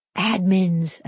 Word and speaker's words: admins: LG heads a team of 250, and they all share one assistant, or admins, as they're called, accent on the ad.